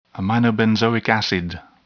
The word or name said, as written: aminobenzoic acid